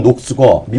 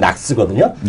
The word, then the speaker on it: KNOX: 그러니까 한국식 발음은 녹스고 미국식 발음은 낙스거든요.